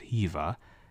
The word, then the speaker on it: Heva: and so her name in the Latin text is, I believe it would be pronounced Heva.